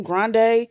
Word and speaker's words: Grande: Just like people thought that Ariana Grande, because that's how you pronounce her last name, Grande, they thought she was of Latin descent and is not.